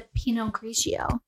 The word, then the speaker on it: Pinot Grigio: It's a Pinot Grigio.